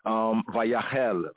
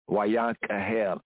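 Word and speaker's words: Wayakahel (ויקהל): Modern Hebrew, as we said, they'll say Vayahel, Vayahel, Vayahel. You hear that? Vayahel, Vayahel. In the modern Hebrew pronunciation, more Afro-Shemitically speaking, Wa and Yakahel, Yakahel. Wayakahel, and he assembled.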